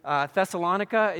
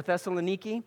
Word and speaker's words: Thessaloniki: Thessalonica is how they would pronounce it, or Thessaloniki, and it's not how we pronounce it.